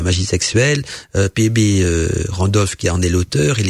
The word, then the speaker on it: Randolph: L'auteur c'est P.B. Radolf, donc Randolph, ça s'écrit R-A-N-D-O-L-P-H.